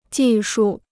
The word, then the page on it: 技术: jì shù